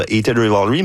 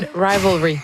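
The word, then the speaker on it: Heated Rivalry: Et évidemment, on a beaucoup parlé dans la dernière année grâce à la trame sonore de Hated Rivalry, mais... Je l'ai bien prononcé, je pense, c'est la première fois. – Non, Hated Rivalry.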